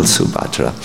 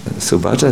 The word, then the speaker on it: Subhadra: Not like Lady Subhadra, he's a male Subhadra. Subhadra, Subhadra, I understand, with a long A.